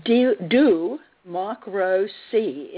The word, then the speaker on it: Duhmocruhsee: Now, Do Mock Rose C is her new film, and it's spelled DUHMOCRUHSEE. Exclamation point.